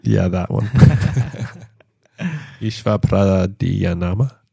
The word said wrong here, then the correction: Ishvara Pranidhana: Ishvara. – Ishvara. – Prani. – Prani. – Dana. – Dana.